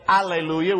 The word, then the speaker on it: alleluia: There are some that would say alleluia without the H in Spanish. The H is silent.